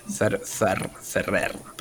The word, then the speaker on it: Ferrer: Y es como, y qué bueno que te haya dicho también Fer, Fernando Ferrer, eh, Ferrer lo pronuncié bien. – Ferrer con R al final.